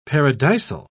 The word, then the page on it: paradisal: British English